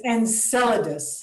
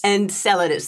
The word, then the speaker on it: Enceladus: and I might need you to correct my pronunciation on that one. But it's Enceladus. – Enceladus. Thank you. I had a feeling I might have said that the wrong way.